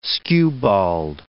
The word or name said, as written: skewbald